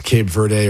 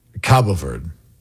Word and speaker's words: Cabo Verde: I'm saying, by the way, it's pronounced both ways. It's pronounced Cape Verde or Cape Verde, but the Portuguese pronunciation is Cabo Verde.